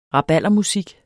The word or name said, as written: rabaldermusik